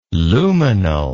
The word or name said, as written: luminal